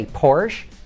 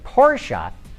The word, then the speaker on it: Porsche: You know, I used to say Porsche. Now I say Porsche because that is how Ferdinand said it.